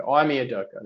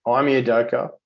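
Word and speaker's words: Ime Udoka: Imi Udoka. Imi Udoka. Imi Udoka. There you go. Yep. Imi Udoka.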